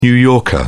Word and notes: new yorker: British English

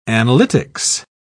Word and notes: analytics: British English

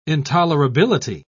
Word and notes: intolerability: British English

